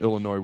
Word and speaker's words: Illinois: It's a short week, but we're going to have plenty of time to talk about Illinois where the S is silent as this week goes along.